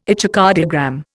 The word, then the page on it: echocardiogram: Indian